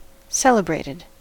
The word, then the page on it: celebrated: American English